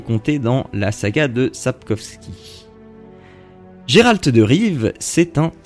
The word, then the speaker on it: Sapkowski: Les romans alors, je ne sais pas si je le prononce bien, mais d'Andrzej Sapkowski. Donc, Sapkowski, c'est bon, je prononce bien.